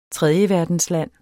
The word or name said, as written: tredjeverdensland